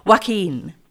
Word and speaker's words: Joaquín: It's extraordinary, but we've also heard a piece, the Concierto de Aranjuez Joaquín Rodrigo, but I would really like you to correct my pronunciation. – Joaquín. No, you're fine.